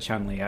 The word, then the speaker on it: Chun-Li: Is it Chun-Li or Chun-Li? – I've always said Chun-Li. I think that's the appropriate pronunciation.